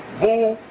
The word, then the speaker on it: Boule: Known as boule, pronounced boule, the group is here. See, boule, boule, B-O-U-L-E.